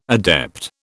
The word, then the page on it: adept: British English